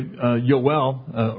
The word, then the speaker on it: Yoel: Anyway, Yoel, or Joel as we pronounce it in English, that means Yah or Jehovah is God.